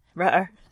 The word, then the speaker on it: Rutter: Rutter. – Rhymes with butter.